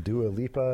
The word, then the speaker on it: Dua Lipa: All the Dilupa. SZA. Is that right? Is that how you say it? – SZA. SZA and Dua Lipa.